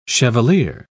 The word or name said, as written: chevalier